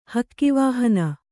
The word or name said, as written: ಹಕ್ಕಿವಾಹನ